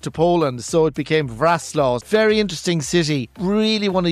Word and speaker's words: Wrocław: So will we start off with, is it Wrocław? Is that how you say it? W-R-O-C-L-A-W. – Yeah, so that is actually pronounced Wrocław, would you believe it?